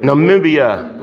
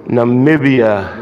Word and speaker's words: Namibia: Namibia. – Broke it down to us. – Namibia.